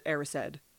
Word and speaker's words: Erised: Mirror of Erised? – Well, I think it depends on who you are, but I think Dumbledore says it Erised. – Erised. Okay. Yeah. So the mirror of Erised,